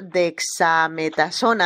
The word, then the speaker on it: dexametasona: Y revelaron ayer que le han dado dexametasona, a ver si lo pronuncio bien, que es Correcto.